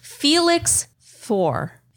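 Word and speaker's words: Félix Faure: Felix Faure. Felix, F-A-U-R-E. Far, it's French.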